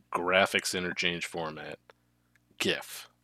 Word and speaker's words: GIF: First of all, it's GIF. It's a hard G.